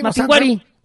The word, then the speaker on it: Mapinguari: No, ah, es que no tiene acento en la I. – No tiene acento en la I. – Mapinguari.